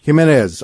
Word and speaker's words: Jimenez: Jimenez.